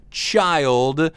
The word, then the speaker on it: Chile: He did a song called Voodoo Child, or if you want to pronounce it the way it was originally done, Voodoo Child. – C-H-I-L-E. – I know it looks like chili, but child.